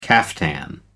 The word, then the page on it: caftan: American English